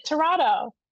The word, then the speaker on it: Tirado: Tirado.